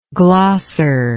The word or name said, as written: glosser